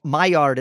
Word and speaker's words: Maillard: Maillard, it's French. It's spelled Maillard.